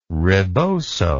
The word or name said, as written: riboso